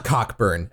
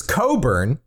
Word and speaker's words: Cockburn: it's not Cockburn, actually. It's Coburn. That's how that name is pronounced.